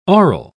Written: aural

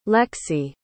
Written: lexi

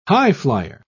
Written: highflier

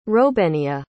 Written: robenia

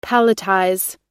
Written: palletize

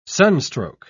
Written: sunstroke

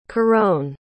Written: carone

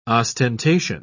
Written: ostentation

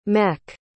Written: mech

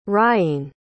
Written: rhyene

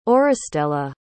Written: auristela

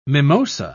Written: mimosa

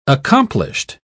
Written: accomplished

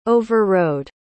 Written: overrode

